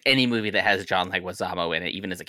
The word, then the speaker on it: Leguizamo: How do you say his name? – Leguizamo, yeah. – Leguizamo, okay.